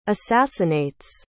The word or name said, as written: assassinates